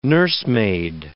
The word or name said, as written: nursemaid